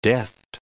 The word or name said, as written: deft